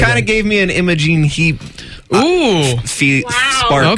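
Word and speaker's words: Imogen: I love Imogene, so... – Okay. You even say it properly.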